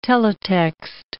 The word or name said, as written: teletext